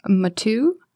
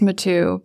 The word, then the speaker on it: Mathieu: So the first one I'm going to share that I finished recently is called Moxie by Jennifer Mathieu, and her last name looks like Matthew, but it's pronounced Mathieu, but it's spelled M-A-T-H-I-E-U, if you want to look it up in our catalog. So I guess it's the French version of Matthew, as far as I know.